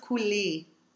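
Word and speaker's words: couleur: We don't say the R at the end of couler. – No, we don't. So that's a silent R.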